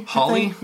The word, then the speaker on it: Hawley: So Legion is an American TV series that was created for FX by Noah Hawley. Is that how we say his name? – Hawley? – Hawley? – Yeah.